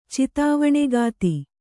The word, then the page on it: ಚಿತಾವಣೆಗಾತಿ: Kannada